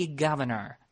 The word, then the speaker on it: governor: Так вот, слово «губернатор» по-английски «governor». «Governor». Governor. Причем вспоминаем, что в британском варианте буква R на конце в принципе не произносится, да, и представляете, что получается.